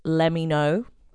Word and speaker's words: Lemmino: I got so much information from this really good YouTube channel called, I think it's pronounced Lemmy Know. – Like, okay. – Lemmy Know, but it's spelled Lemmino.